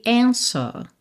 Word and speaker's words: answer: Então, aqui também é interessante, a gente também não fala answer, ou seja, a gente não pronuncia esse W mesmo com som de U, né? Answer, tá? Então, answer. E poderia ser answer, mas como ele não enrola o R, answer.